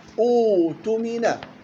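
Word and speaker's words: اؤتمن: فتبدل الهمزة الساكنة حرف مد واوا مجانسا لحركة الهمزة الأولى الضم، فيصبح مد البدل يمد بمقدار حركتين حال ابتداء، وتنطق أوتمنا.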